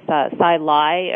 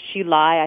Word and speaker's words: Hsi Lai: Again, that's Taoist, but it's Sai Lai or Shi Lai. I think some people pronounce it H-S-I-L-A-I is the name of the author.